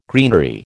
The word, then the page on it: greenery: American English